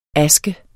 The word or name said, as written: aske